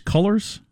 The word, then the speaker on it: Cullors: So one of those was Patrice Cullors? – Yeah. – Is that what you said? – Cullors, I think it's pronounced.